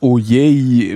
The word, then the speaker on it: Ojej: Also Ojej, also ein offenes ein offenes O sozusagen.